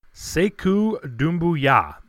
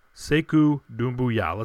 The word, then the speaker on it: Sekou Doumbouya: I was putting the emphasis on the wrong syllable, unfortunately, and I was going early in the season and throughout most of the year, Sekou Doumbouya. And I was close. I had all the syllables pronounced correctly, but the emphasis, like I said, wrong syllable. So Sekou Doumbouya. Sekou Doumbouya. Let's get this right from now on.